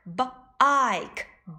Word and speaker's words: bike: 原来呀，它们都不发音。 Lake l a k e lake bike b i k e bike rose r o s e rose Kate k a t e Kate 这个 e 呀，我们也叫做哑音 e。